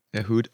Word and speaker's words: Ehud: Ehud? Okay, so, that would be the correct pronunciation.